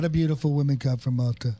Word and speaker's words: Malta: Is that pronounced Malta? – It's Malta.